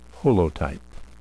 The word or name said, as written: holotype